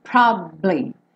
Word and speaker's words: probably: Probably, probably. Tá? Então não é probably. Se você falar probably, vai soar como se só tivesse duas sílabas, mas você descansa um tempinho ali.